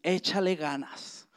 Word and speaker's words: échale ganas: So we there's a phrase in Mexico we say a lot, and it's échale ganas.